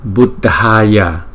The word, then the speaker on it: Buddhaya: Chữ D, chữ H chúng ta đọc là da, da, da, da. Cho nên là chữ này đọc là da, da, Buddhaya, Buddhaya, Buddhaya, Namo Dhammaya, Dhammaya, Dhammaya, Namo Sanghaya, Sanghaya, Ga, Ga, Ga, Ga, Ghaya, Sanghaya, Namo Sanghaya.